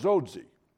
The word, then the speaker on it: Dzodze: D-Z-O-D-Z-E. Now you pronounce that. It's pronounced Zodzi.